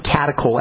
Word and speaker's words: catechol: The breakdown products of estrogen are called catechol estrogens. That's spelled C-A-T-E-C-H-O-L, catechol estrogens.